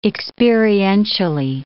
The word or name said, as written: experientially